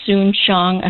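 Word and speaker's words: Soon-Shiong: So, as you probably recall, the LA Times was purchased by Dr. Soon Shiong. I hope I'm saying his name correctly. – Oh, I think you are.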